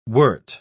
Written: wort

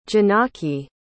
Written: Janaki

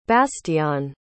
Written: bastiaan

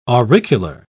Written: auricular

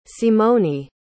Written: simoni

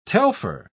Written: telfer

